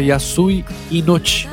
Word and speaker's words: Yasui Inochi: me corrija se eu estiver errado, Felipe, é Yasui Inochi. É isso aí, perfeito.